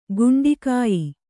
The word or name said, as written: ಗಂಡುಗೆಯ್